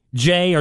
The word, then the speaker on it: Ja: I'm gonna ask you how Jay or Ja Morant's first name is pronounced.